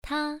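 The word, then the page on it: ta: tone 1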